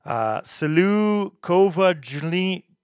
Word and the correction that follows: Silou kovadliny: Kovajlini, Oh, man, I am terrible at trying to pronounce these. Google Translate says it translates to the power of the anvil, which is fucking cool. That's awesome. – Silou kovadliny. – There you go. That's what it sounds like in Czech.